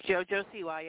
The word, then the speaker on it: JoJo Siwa: Is it JoJo Siwa? – It's JoJo Siwa, yeah.